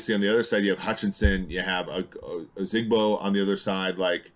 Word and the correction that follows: Ojabo: Ojabo on the other side that can provide pressure.